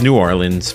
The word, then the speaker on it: New Orleans: See, I'm saying it right. It's New Orleans for you guys that have never been down there.